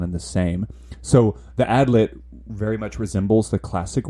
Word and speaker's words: Adlet: pronounced, it's spelled A-D-L-E-T, mm-hmm. but it looks like it's pronounced Adlit, actually.